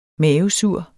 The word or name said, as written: mavesur